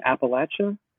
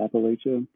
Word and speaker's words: Appalachia: Hillbilly Elegy, which is, I think that's it's Glenn Close, and it's about life in Appalachia. Which Emery told me that's how you say it. It's not Appalachia.